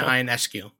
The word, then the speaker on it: Ionescu: I think it's Sabrina Ionescu. I think that's how you say it.